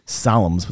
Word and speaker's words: Psalms: I mean, I've heard you say before that, you know, because I've asked you, Tehillim, Psalms with the silent P, they're in everything.